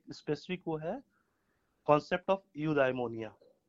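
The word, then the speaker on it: eudaimonia: तो कहते हैं यूडाइमोनिया, which means it's a Greek word.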